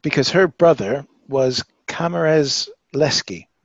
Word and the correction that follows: Kazimierz: Did I get that right? No, I didn't. I got the G in the middle. tell me how to say it, Alina. – Kazimierz. – Yes, that.